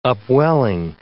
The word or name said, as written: upwelling